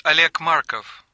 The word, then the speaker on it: Oleg Markov: Yes, it's Oleg Markov. – How do you spell that? – O-L-E-G Markov. M-A-R-K-O-V.